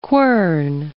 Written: quern

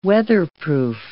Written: weatherproof

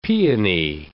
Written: peony

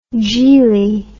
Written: jeelie

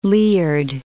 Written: Liard

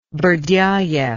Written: Berdyaev

